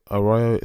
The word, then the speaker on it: Arroyo: Arroyo, A-R-R-O-Y-O. It's a Brazilian Portuguese surname, so I'm going to go with Arroyo as that's how it was pronounced and by one of the commentators during the fight.